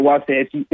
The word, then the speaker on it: Waxahachie: I see the – am I saying it right? Is it Waxahachie? Is that where you're from? – Yes, sir, Waxahachie.